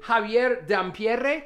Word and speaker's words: Javier Dampierre: And in the studio, our two guests who are arguing will be Javier Dampierre. Did I pronounce that correct? – Oh, totally. Perfect.